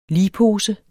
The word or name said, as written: ligpose